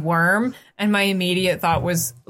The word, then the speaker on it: wyrm: Yeah, the W-Y-R-M. – I have always pronounced it worm, but I heard people pronounce it as Wyrm, and I'm like, that doesn't seem right, but sure. – It's still worm.